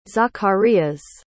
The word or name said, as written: zacarias